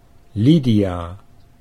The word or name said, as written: Lydia